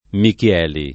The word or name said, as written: Michieli